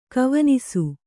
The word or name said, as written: ಕವನಿಸು